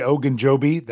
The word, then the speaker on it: Ogunjobi: A player that was added today was Larry Ogunjobi. That's how you say it, Jeff.